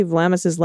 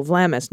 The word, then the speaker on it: Vlamis: The first syllable of reporter Kelsey Vlamis's last name rhymes with fam, not fame. Oh, Vlamis, not Vlamis.